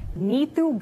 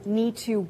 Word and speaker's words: Neetu: Nitu Garcha is the authentic Punjabi pronunciation that my parents gave me rather than Neetu Garcha.